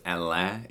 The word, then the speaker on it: Alain: So my first name is pronounced Alain. It's a French name, so it's not spelled the way it sounds.